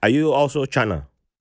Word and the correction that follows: Shona: That's Sh- Shona. Shona. Shona. Sorry, Shona. sorry. Shona. I, I, just went with the uh, English pronunciation.